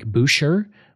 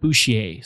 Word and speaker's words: Boucher: Now, his name could be pronounced Frank Boucher, but because he is half French-Canadian, and I looked up various ways to pronounce it with other hockey players with the same last name, and they came up with Boucher. So that's how I'm going to be pronouncing his name through this episode.